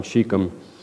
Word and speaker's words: Shechem: a place called Shechem. Now, Rich was talking about Bible pronunciation names in Sunday school, and there was like five different ways to pronounce this too, Shechem. That's what I settled on, Shechem.